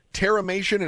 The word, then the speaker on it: terramation: It's called terramation, and am I saying that correctly? – Yeah, you are.